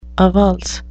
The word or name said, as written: avulse